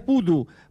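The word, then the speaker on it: Bodø: La ciudad es Pudu, porque esa O con el palito al medio se pronuncia como una U.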